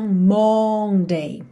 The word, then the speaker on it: Monday: Se escreve com Monday, mas esse O não vai ter esse som Monday, tá? E sim Monday, Monday.